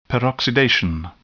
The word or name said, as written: peroxidation